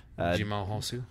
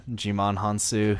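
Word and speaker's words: Djimon Hounsou: Jimon Hansu. – Yeah, Jimon Hansu, who plays the Fisherman King in Aquaman.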